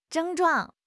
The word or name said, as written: 症状